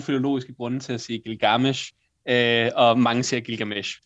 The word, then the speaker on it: Gilgamesh: Udtaler jeg det egentlig rigtigt, eller skal man lægge trykket anderledes? – Jamen, det er et godt spørgsmål. Altså, jeg siger Gilgamesh. – Gilgamesh.